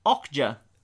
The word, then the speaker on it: Okja: You, on the other hand, Michael, you chose to spend your time in June watching Okja. – Yep. – Am I pronouncing that right? – Yeah, you are.